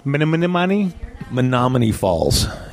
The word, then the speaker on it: Menominee Falls: Hi, we're bringing this podcast from on location at Don Harbor's in Menominee Falls, Wisconsin. I'm here with Dave. – How do you pronounce that again? – Menominee Falls. It's an Indian name, Dave.